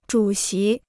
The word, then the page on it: 主席: zhǔ xí